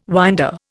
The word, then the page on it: winder: Indian